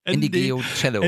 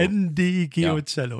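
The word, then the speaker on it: Ndegeocello: So Ndigeocello. – Ndigeocello. – Ja. Also genau. Sie wird auch deutlich anders geschrieben, als wie sie am Ende ausgesprochen werden soll.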